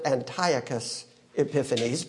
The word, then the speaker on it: Antiochus Epiphanes: a man who was named Antiochus Epiphanes in history. Or you can pronounce it Antiochus Epiphanes.